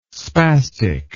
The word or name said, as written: spastic